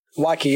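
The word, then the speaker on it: waakye: You know, another thing that I add, I wanted to ask, how do I pronounce this dish properly so I don't butcher it? Wakki? – Wakki, yeah.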